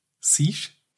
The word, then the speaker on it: Siege: Die nächste Neuigkeit ist Rainbow Six Siege. Mein Gott, aussprechen. Siege? – Siege. – Siege, genau. Da gibt es ein kostenloses DLC.